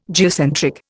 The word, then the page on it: geocentric: Indian